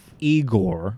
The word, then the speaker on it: Igor: It's Ior Dyatlov. – Igor. – Igor Dyatlov.